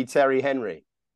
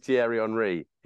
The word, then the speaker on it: Thierry Henry: It's as simple as Thierry Henry. In Britain, he'd be Terry Henry. In France, he's Thierry Henry.